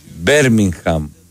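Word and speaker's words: Birmingham: Οι πόλεις προφέρονται όπως λέει το στοίχημα. Πίτερμπρο, Πιτερμπόρο, Μίτλεσμπρο. Ό,τι γράφει εδώ πέρα το δελτίο. Μπέρμιγχαμ, τέλος. – Όχι, όταν είχα πρωτοέρθει. Μπίρμιγχαμ, έτσι λέγανε.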